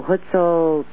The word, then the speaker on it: Huitzilopochtli: No, Huitzilopochtli.